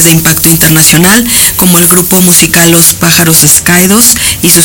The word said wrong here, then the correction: Pájaros Caídos: sello editorial ¿Escaídos o Escaídos? ¿Cómo lo pronuncias? – Pájaros Caídos. – Ah, la S no.